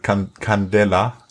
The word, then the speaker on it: Candela: Candela ist wirklich kommt aus dem Lateinischen für Kerze. Betonung übrigens auf der zweiten Silbe und ist die SI-Einheit der SI-Basisgröße Lichtstärke.